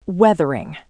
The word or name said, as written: weathering